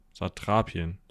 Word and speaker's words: Satrapien: Ein langes A. Satrapien. Satrap.